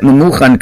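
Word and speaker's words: Memuchan (ממוכן): It's written one way, but pronounced another way. I pronounced that Memuchan correctly, but it's written Mumkan, which means there's a blemish here.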